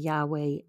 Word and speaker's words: Yahweh: I think we would, as Americans, say Yaweh Yireh, Y-A-H-W-E-H. And the A and the E is put in there so that you know how to really pronounce it. Like, true Hebrew doesn't even have the A and the E in there. And then the Yireh is Y-I-R-E-H, and it means the Lord will provide.